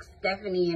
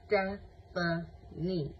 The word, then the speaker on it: Stephanie: And she was like Stephanie, and I was like, excuse me, you don't say my name like that. It's Stephanie.